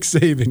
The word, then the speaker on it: Saban: Saban, am I saying that right? – Oh, my God, you are just fortifying and just confirming the name of this segment. It's Nick Saban, yes.